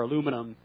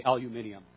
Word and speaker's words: aluminum: aluminum or aluminum boat, get the right pronunciation here, and we say aluminum.